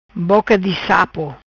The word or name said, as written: Boca Di Sapo